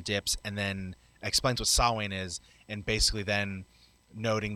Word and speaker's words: Samhain: so I've always pronounced it Samhain, but you're saying it's Samhain? – It's in the old Celtic, it's pronounced Samhain.